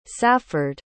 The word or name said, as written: safford